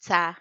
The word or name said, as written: tsa